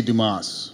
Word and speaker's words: Dumas: Is there a proper pronunciation? – I feel like they would say Dumas.